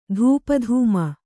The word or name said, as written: ಧೂಪಧೂಮ